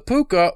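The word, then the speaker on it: púca: Uh, cause in Ireland, in uh, Gaelic, all C's are all K's pronounced, so it would be, still be Puka.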